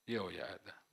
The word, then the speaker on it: Jehoiada: Ma i leviti non si affrettarono, allora il re chiamò Jehoiada, chi mi aiuta come si pronuncia? Jehoiada, giusto.